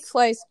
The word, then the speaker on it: Fleiss: Oh, yeah, so Mike, how do you say his name? Fleece, Fleiss? Mike Fleiss.